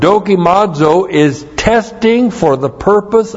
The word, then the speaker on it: dokimazo: D-O-K-I-M-A-Z, long O, dokimazo.